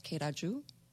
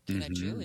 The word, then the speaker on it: Kereju: Hawk clan, which the hawk represents our warrior clan, and that was Keraju. Keraju, it's spelled K-E-R-E-J-U.